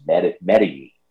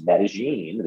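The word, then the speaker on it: Medellín: I mean, I grew up calling this Medellin. I mean, am I crazy? But what I was told on Friday is correct. It's Medellin. This is the way the locals say it here in the country of Colombia, in South America.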